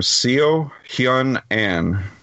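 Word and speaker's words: Ahn Seo-hyun: And I'm going to try my best with this name, Seo Hyun Ahn, who I believe is the main girl in the movie. – Exactly. Yeah, you pronounced it exactly correctly, man.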